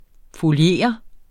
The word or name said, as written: foliere